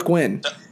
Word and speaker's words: Nguyen: It's just W-I-N. W-I-N. Nguyen. – W-I-N, win.